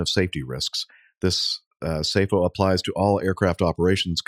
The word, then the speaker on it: SAFO: The FAA issues a SAFO, I guess is the way you pronounce it S A F O, SAFO, SAFO, following recent close calls.